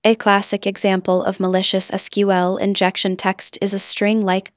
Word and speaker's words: SQL: SQL, pronounced SQL, is the language used to communicate with databases.